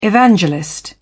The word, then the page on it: evangelist: British English